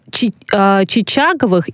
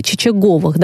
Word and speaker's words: Чичаговых: Это мы будем говорить про отца и про сына Чичаговых или Чичаговых. Давайте сразу тоже разбираться, потому что даже если смотреть различные ресурсы в интернете, ну, очень по-разному дают информацию и ударение. Да, Василий и Павел. Фамилия-то какая? – Можно и так, и так. – И так, и так. Прекрасно. – Оба варианта правильные.